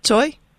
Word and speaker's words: Tsui: Well, my last name is Choi, T-S-U-I, pronounced as Choi. I am a board member. I'm sitting on the board of directors for Asian Access. – Okay, so it's Francis Choi? – Yeah.